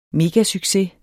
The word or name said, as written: MEGASUCCES